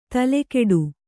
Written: ತಲೆಗೆಡು

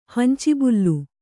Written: ಹಂಚಿಬುಲ್ಲು

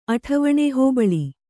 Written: ಅಠವಣೆ

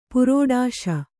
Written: ಪುರೋಡಾಶ

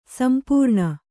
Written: ಸಂಪೂರ್ಣ

